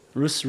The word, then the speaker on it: Ruth: which is by Megillas Ruth, right? Three pronunciations. How do you like that? Rus, Ruth, Ruth, right?